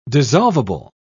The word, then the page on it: dissolvable: British English